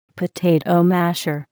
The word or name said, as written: potato masher